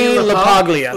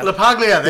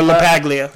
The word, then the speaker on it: LaPaglia: I could never pronounce his last name. Now. – Anthony LaPaglia. – LaPaglia, thank you. – Or LaPaglia.